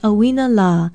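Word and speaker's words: Auina La (ʻauinalā): Aloha, I'm Leilani Poliahu for Hawaii Public Radio, and our Hawaiian word of the day is Auina La. Auina is spelled Okina, A-U-I-N-A, and La is spelled L-A with a kahakou or makron over the vowel.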